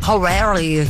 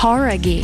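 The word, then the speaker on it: Jauregui: Horaghi, não é? – Horaghi. – Horaghi. – Então a gente tava falando errado, cara.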